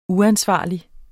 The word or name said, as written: uansvarlig